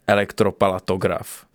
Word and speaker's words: Elektropalatograf: A teď já se to pokusím vyslovit, když tak mě opravte. Elektropalatograf. – Je to tak.